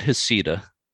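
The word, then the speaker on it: Heceta: They pronounce it Hisseta.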